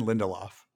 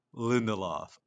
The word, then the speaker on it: Lindelof: Damon Lindelof. – Lindelof. All right. All right. Good. – It's like Spindelof.